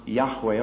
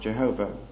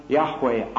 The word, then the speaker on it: Yahweh: J-H-V-H, pronounced Y-H-W-H, and probably pronounced Yahweh or something like that, certainly never pronounced Jehovah.